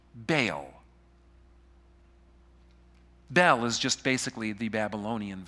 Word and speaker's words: Bel: But the second thing relates to another name for Marduk, another name that you'll find in the Bible, pronounced Bel. Not like Bell, like in Beauty and the Beast, all right?